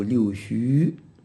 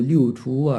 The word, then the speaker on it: 六畜: 字啊，在这里叫六畜啊，这个畜字在古时候也念戌，六戌，到后来呢，这个六戌啊，这个名字就读畜，六畜。那么虽然读六畜啊，也有人读六戌，这个如果有人读六戌啊，我们不要说人家念错了啊，因为中国这个字读音呢，不那么简单的，有多种的读法。